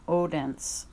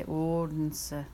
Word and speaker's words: Odense: Odense, Denmark. Now, you might pronounce it Odense, O-D-E-N-S-E, but it's Danish. They say Odense. The O is different, and they pronounce that E in some way, Odense.